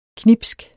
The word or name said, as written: knibsk